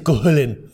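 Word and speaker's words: Cú Chulainn: Se le dice un chingo de formas porque, pues, uh-huh, sí, como Cuculín. – Como Cuculín, sí, señor. – Chistes paméticos. Es que era un güey que se llamaba Cuchulain, pero se dice Cuculín.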